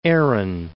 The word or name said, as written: Erin